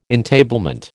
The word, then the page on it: entablement: American English